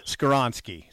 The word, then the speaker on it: Skoronski: I butcher his last name. So I was going to say Skoronski. Help me out. – You butchered it. Skoronski. Skoronski. Yeah, Skoronski.